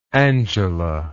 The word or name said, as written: angela